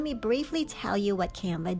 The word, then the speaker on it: Canva: It's spelled C A N V A, that's Canva.